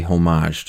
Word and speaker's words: homage: I think the H is silent. – Welcome homage. To English. – Welcome to the Straits of Hormel. – Actually. – But it's like herb.